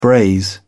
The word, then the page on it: braise: British English